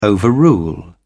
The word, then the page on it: overrule: British English